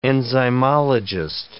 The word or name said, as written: enzymologist